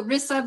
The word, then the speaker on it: Rissa: Oh, Rissa, but people call me Nathan too, so it's fine.